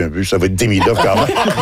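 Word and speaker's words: Demidov: Et si on veut être pointu, c'est die. Il y a un petit Diemidoff. L'accent est sur la deuxième syllabe, donc Diemidoff.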